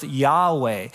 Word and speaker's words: Yahweh: It looks like this. It's pronounced Yahweh.